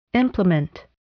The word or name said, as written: implement